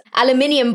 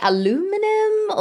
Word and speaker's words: aluminium: Because it's aluminium bottles, or you guys say aluminum, or there's always a pronunciation one. – Yeah, in the Bahamas, they actually said aluminium as well. It must be a Commonwealth thing.